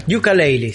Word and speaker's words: Yooka-Laylee: Yooka-Laylee, sí.